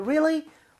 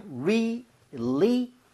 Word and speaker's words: really: Really? What are the odds? 그렇죠? 정말로? 진짜로? 자, 오랜만에 심플하지만 요거 발음 다시 한번 해볼까요? Really가 아닙니다. 얼로다가 돌아오지 마세요. 그냥 really? Really?